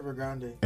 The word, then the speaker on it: Evergrande: Now, I'm sure you guys heard, or maybe you didn't, about the, is it Evergrande or Evergrande? How do you spell that? – Evergrande. – Evergrande, like Ariana Grande.